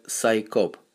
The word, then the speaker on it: CSICOP: Аббревиатура CSICOP произносится как SciCop, то есть ученые, scientists, копы.